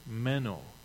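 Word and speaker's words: meno (μένω): And that Greek word is meno. It's not M-E-N-N-O, as in Menno Simons or Mennonites, but it's M-E-N-O with a line on top of the O. Menno. It's said that it's pronounced exactly the same way,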